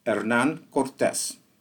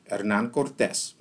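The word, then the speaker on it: Hernán Cortez: It's Hernan Cortez, but I'm going to ask you to see if you can pronounce it in Spanish. Hernan Cortez. So for yous who want to try to roll the R's, give that a shot.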